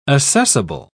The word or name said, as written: assessable